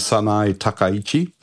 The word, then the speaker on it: Sanae Takaichi: Well, I mean, so the pronunciation is Sanae Takaichi.